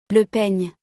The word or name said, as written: Le peigne